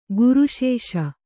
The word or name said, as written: ಗುರುಶೇಷ